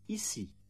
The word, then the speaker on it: ici: However, when C is followed by E or I, the sound changes to S. Sans, ici. Listen and repeat. – Sans, ici.